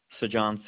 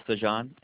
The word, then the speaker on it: Sajan: Sajan Singla. – Can you repeat that? – Yes, ma'am. I can spell it for you. First name is Sajan, S as in Sam, A-J-A-N.